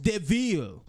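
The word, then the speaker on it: De Vil: It's spelled devil, but it's pronounced DeVille.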